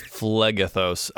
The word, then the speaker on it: Phlegethos: Archduke Belial, ruler of Felagros. – Ooh, I missed that part. Hang on. – Ruler. – Oh, it's Phlegethos. I can't pronounce anything. It's Phlegethos.